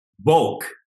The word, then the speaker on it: Volk: Volk. Not the woke, the no. Volk. V-O-L-K. – V-U-L-K. – No, V-O-L-K. The folk. – Aha. No, we used the letter F for that in Swedish, but it's in German it's V-O-L-K, yeah.